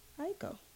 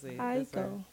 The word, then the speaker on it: Aiko: Oh, how you say her name? – Aiko. – Don't say it. – Aiko. That's how she say it. She says, I'm Janae Aiko. That's what she say every time.